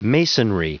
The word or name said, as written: masonry